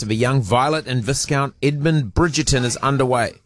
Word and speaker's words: Viscount: Viscount. – It's got an S in it. Viscount. It's like discount. Put it with a V. – It's like the S in disco. You don't pronounce it.